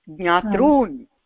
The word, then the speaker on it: ज्ञातॄन्: दीर्घरूकारः उच्चारणीयः ज्ञातॄन्